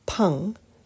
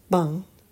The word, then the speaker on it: peng: However, the Chinese word for this, peng, sometimes pronounced bang, doesn't mean to ward something off at all.